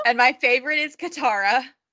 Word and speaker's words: Katara: Yeah, Katara.